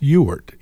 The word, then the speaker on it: Ewart: My middle name, which I generally won't share with anybody because they all mispronounce it, it's Ewart, E-W-A-R-T.